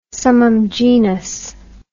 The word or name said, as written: summum genus